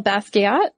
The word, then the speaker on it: Basquiat: Jean-Michel Basquiat.